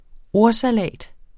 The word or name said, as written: ordsalat